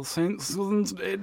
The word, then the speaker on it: St. Swithin's Day: St. Swithin's Day. – Oh, you're pronouncing it quite well. – Yeah. St. Swithin's Day.